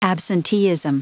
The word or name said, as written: absenteeism